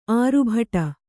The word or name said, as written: ಆರ್ಭಟ